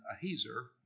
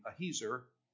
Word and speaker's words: Ahiezer: But the son of Dan, Ahiezer, that's the way you pronounce his name, Ahiezer, apparently asked that to be changed.